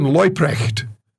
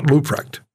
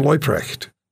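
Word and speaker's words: Leuprecht: Christian Loyprecht, not Luprecht.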